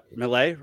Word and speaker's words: Milei: Milei, right? That's how you pronounce it? – Yeah.